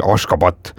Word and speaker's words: Ashgabat: Küll nagu valdav osa nendest elab siis pealinnas, mida noh, paljud meist võibolla nagu lihtsustatud korras tunneksid Ashgabadina, aga tegelikult on ta nii kirjapildi mõttes kui ka häälduse mõttes ikkagi Ashgabat.